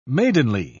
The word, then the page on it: maidenly: British English